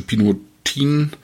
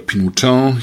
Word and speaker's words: Pinotin: Cabernet Blanc und der rote Pinotin oder Pinotin, je nachdem, wie man das aussprechen möchte,